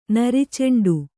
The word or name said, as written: ನರಿಚೆಂಡು